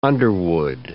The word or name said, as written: underwood